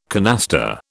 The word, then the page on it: canasta: British English